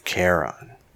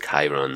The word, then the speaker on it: Chiron: Yeah, I think it's pronounced Chiron, though. I think there's an I sound in it. Charon, but I think there's an I sound in it, because, you know, it's not English.